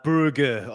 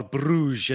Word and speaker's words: Brugge: Uh, Brugge, or Bruges, as many people like to say it or pronounce it, they had another unbelievable result.